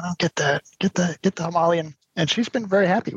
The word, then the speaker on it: Himalayan: Have you guys heard anything about like people's, you know, enjoyment of the Himalayan? Like, is it reliable? Is it, I don't know. – We have one acquaintance in North Carolina that has a Himalayan.